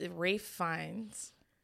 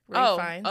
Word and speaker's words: Ralph Fiennes: It's Rafe Fiennes. – Who? Rafe? – Rafe. – Raff. Wait, that's the name of the character? – No, it's an actor. Rafe Fiennes.